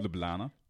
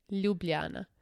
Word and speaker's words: Ljubljana: How did you say it? – Ljubljana. – No, Ljubljana.